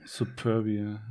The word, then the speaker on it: Superbia: Ich glaube, es mit P, ne? Superbia. Ja.